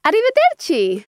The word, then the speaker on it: arrivederci: It's arrivederci.